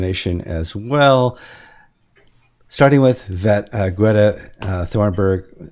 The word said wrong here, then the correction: Thunberg: That's not how you pronounce her name. How do you pronounce her name? Greta Thunberg.